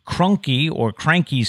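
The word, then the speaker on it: Kroenke: Next on the list at $10.54 billion was Cranky Sports and Entertainment. It's spelled K-R-O-E-N-K-E. I'm really Cranky.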